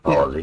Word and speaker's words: polity: polity? – Yep. – Is that how you say it? – Yep, polity. Yeah.